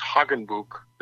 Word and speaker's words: Hagenbuch: he would have pronounced Hagenbuch, very German. And when I'm around any German people and I say my last name is Hagenbuch, they know exactly how to spell it.